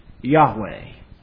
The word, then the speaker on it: Yahweh: And because the vowels of another word, Adonai, were combined with those consonants, the name has come down to us as Jehovah. We think that it was probably pronounced Yahweh.